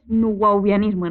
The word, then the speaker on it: Nuwaubianismo: A veces lo digo con V, a veces con W. Se escribe con W. Es nubauvianismo en realidad.